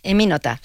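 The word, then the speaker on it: Emmy Noether: Te concedieron hace unos años el premio Eminöther, ¿se pronuncia así? – Eminota, sí, es alemán.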